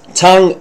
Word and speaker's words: tongue: Tongue is a word which is spelled T-O-N-G-U-E. It's actually funny that when you pronounce it, it's only three phonemes, three symbols, but in spelling it is six.